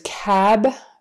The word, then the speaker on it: cab: So a cab means the like a taxi. Cub, a short U, is a baby bear. I see a cab. Short A, the taxi. I see a cub. Short U, the baby bear. I see a cab. Short A, taxi.